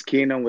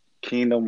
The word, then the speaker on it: KNGDM: So it's Kingdom without the syllables. Kingdom without the syllables. Mm. You sound it out, still says Kingdom, though.